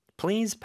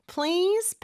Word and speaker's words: please: PL인데 L 발음이 더 강하게 나야 돼요. 그래서 please, Z 발음도 주의하면서 please 이렇게 길게 모음 소리가 나야 되겠죠. 그 앞에 살짝 P를 넣으면 돼요. Please, please.